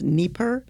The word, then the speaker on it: Dnieper: W-E-I-R. And there's a word in here, and I believe it's pronounced Dnieper. It's D-N-I-E-P-E-R, and that's a river, that's a really large river that runs through Ukraine.